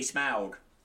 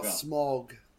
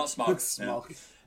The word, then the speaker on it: Smaug: And it is definitely Smaug. – Not Smaug. – No. Smaug.